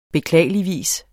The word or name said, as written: beklageligvis